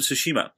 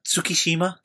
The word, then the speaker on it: Tsushima: Sean Layden, on, I believe, speaking to Sid Schumann, he said, right out of the gate, Death Stranding, we're going to have Ghost of Tsushima, which he pronounced really beautifully. And I'm sorry, Young, love you, but Tsukishima? Come on, dude.